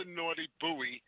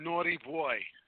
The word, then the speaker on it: Naughty Boy: You know, it's not the Naughty Buoy. The Naughty Boy. – Yeah, I know it's Naughty Boy, but I say buoy because that's how it's spelled.